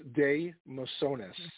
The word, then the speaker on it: de Mesones: It is pronounced Day Mesones.